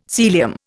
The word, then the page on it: Sealyham: Indian